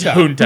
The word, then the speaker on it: junta: What is, junta. It's junta. Junta. Yeah, yeah, it's, you said it right.